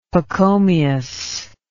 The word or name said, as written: Pachomius